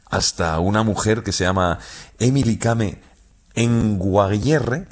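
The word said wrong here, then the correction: Kngwarreye: lo he dicho mal. – Emily Kame. – Con Guarreye.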